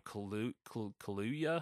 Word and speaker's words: Kaluuya: Some really good acting in it from Daniel Kaluuya.